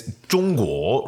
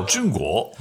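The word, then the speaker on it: Zhongguo (中国): In China, they don't call China China. It's Zhongguo, right? So Zhongguo.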